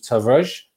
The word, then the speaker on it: Tavares: If you want to find out more about Nuno Tavares, or Tavares, however we're pronouncing it, I mean, I've been told time and time again that you don't actually pronounce the R from listening to other pronunciations, so it comes across as Tavares,